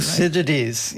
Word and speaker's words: Thucydides: Thucydides.